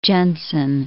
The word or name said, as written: Jenson